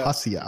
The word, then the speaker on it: Abjasia: Oxetia y Abajia, no sé cómo se, ni siquiera se pronuncia. – Abjasia, Abjasia.